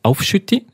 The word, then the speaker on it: Aufschütti: Ein Hotspot würde ich mal sagen, der ist ganz nahe von meinem Zuhause, das ist die Aufschütti auf Schweizerdeutsch ausgesprochen.